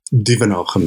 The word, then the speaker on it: Duvenhage: Duvenhage, Mel.